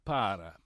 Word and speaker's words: Parra: Okay, so Zach Davies is the pitching prospect we are trading off, a left-hander, for Gerardo Parra. P-A-R-R-A. – You got that right. I think he would like that pronunciation.